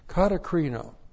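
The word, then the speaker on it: katakrino (κατακρίνω): Looks like that in the Greek, K-A-T-A-K-R-I-N, long O. Katakrino.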